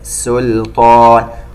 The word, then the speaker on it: سلطان: سلطان، ننتبه إلى السين لا تتحول إلى صاد أثناء النطق بكلمة سلطان، واللام مرققة. سلطان